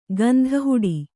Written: ಗಂಧಹುಡಿ

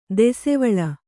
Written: ದಾಸಿವಾಳ

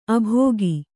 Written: ಆಭೋಗ

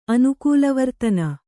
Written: ಅನುಕೂಲವರ್ತನ

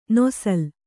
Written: ನೇಸಲ್

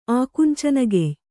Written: ಆಕುಂಚನಗೆಯ್

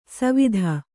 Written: ಸವಿಧ

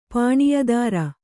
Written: ಪಾಣಿಯದಾರ

